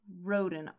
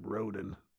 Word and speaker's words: Roden: Roden, R-O-D-E-N. I feel like there must be a better way of pronouncing that, but... – Nope, we're going to go all American, get real guttural in there, like Roden.